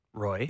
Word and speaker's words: Roy: Okay, Matt Roy is pronounced Roy.